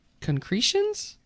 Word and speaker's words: concretions: The boulders on this New Zealand beach are concretions? Did I say that right? – Yep.